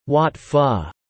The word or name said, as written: Wat Pho